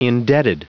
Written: indebted

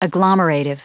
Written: agglomerative

